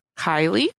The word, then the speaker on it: Kaili: And then my other pick, I'm going to stick with the K's and go with, I was going to say it's Kaylee, but I think Chris pronounced it as Kylie. K-A-I-L-I.